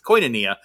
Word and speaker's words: koinonia: I'm going to mispronounce that. I mean, it's spelled K-O-I-A. Anyway, it's koinonia.